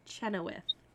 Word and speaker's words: Chenoweth: Kristen Chenoweth.